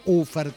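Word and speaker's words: Ofärd: Y no se dice Ofard, se dice Ufer, como bien me recuerdan una y otra vez.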